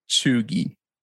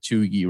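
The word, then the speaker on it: cheugy: chuggy.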